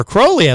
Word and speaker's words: Crowley: Adrian Crowley, Crowley, as I learned, is actually how I say his name in the second hour.